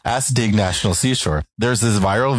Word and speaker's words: Assateague: Assateague.